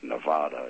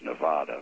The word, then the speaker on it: Nevada: I know if you're from the state of Nevada, it's pronounced Nevada. But in Missouri, the little town on the west edge of Missouri, on the Kansas border, is called Nevada.